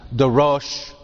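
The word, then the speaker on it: darosh (דרש): The word getting translated "carefully search" and so forth is the repetition of the verb darash, which would be pronounced darosh, or darash darosh. The second one should say darosh.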